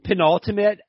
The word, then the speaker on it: penultimate: penantulum. Is that the word? – Penultimate episode of Dana White's Contender Series, right? – I think that's right.